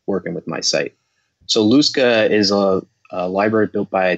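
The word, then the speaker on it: lusca: So Luska, and you are pronouncing it right, at least as far as I know. That's how I would pronounce it.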